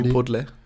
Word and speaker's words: Chipotle: Chipotle.